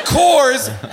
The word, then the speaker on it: Coors: And the word Coors has one syllable. Coors. – Coors. – K-O-O-Y-E-R-S.